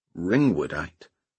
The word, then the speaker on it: ringwoodite: "Z-waves caused by the water being released from a mineral known as ringwoodite."